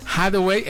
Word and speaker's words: Hathaway: Hathaway, efectivamente, yo no sé el inglés, estudié en una escuela de la 4T, por eso no me alcanzó.